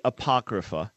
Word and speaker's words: Apocrypha: Yeah, it's called the Apocrypha. These books are Apocrypha. Apocrypha. Apocrypha.